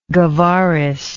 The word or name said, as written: Guevarist